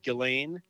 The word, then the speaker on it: Ghislaine: Some, I think a flat American accent would be Jill N, like that.